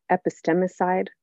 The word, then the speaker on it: epistemicide: And I'm thinking about a word that you actually introduced me to, epistemicide. Did I say that right? – Mm-hmm. You sure did.